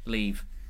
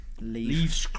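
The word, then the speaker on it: Liev: Lieb. – Leave.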